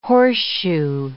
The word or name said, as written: horseshoe